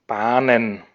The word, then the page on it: Bahnen: [ˈbaːnən]